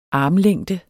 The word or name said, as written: armlængde